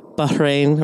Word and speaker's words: Bahrain: And I always say this wrong, but Bahrain or something like that. That's exactly. Oh, yeah, that's great.